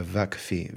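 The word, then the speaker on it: Vakfı: But in the Turkish pronunciation, they call it a Vakfi, V-A-K-F-I.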